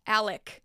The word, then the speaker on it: Alex: And by the way, while I was editing part 2, I caught a few times where I accidentally said Alex instead of Alec. It's confusing because it's spelled Alex, but it's pronounced Alec.